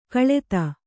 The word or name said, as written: ಕಾಳೆದೆ